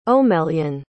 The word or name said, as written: Omelyan